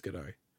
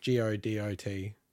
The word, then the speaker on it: Godot: I think it's Godot. So okay. it's G O D O T, but I believe you pronounce it Godot.